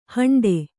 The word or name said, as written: ಹಂಡ